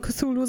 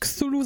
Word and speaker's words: Cthulhu: Aber es gab durchaus Briefe von Mr. Lovecraft himself, der klargestellt hat, er wollte eigentlich eher so eine, ich glaube, ans Afrikanische angelehnte Aussprechweise oder ans Karibisch angelehnte Aussprechweise haben, die eigentlich nicht Cthulhu sagt, sondern Cthulhu.